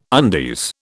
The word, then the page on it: undies: British English